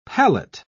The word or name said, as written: pallet